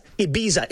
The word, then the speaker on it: Ibiza: So it's Ibiza. It's a Z. I say it like a Z.